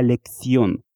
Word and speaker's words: lección: «И ahora vamos a la tercera parte de la lección» с ударением на последнем слоге в слове «lección».